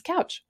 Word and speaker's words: Kautsch: Now, Jen Couch, now her last name is spelled K-A-U-T-S-C-H. Yeah, I know. It's like my maiden name. It's kind of different, but hers is simply pronounced Couch.